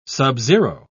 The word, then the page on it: subzero: British English